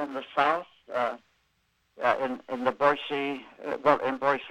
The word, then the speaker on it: Boise: And people should note that you said Boise, not Boise. It's yes. – pronounced with an S, not a Z.